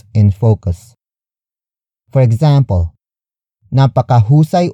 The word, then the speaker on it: napakahusay: Napakahusay umarte ni Nora. Napakahusay umarte ni Nora.